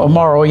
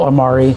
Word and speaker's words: amaro: Is it Amaro? – So Amaro, yeah, but plural Amari. – A-M-I-R-O? – A-M-A-R-O.